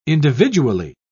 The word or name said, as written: individually